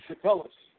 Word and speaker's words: Catullus: You are not conticulous. Is that how you pronounce that again? – Catullus. – Catullus, thank you.